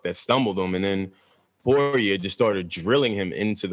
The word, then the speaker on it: Poirier: Poirier? – Poirier.